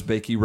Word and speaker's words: Boeke: Bakey Road Baptist Church, spelled B-O-E-K-E. Not exactly how you pronounce it, but he started that in 72.